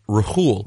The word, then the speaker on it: Rhule: And I'm pretty sure his name is actually pronounced Rhule.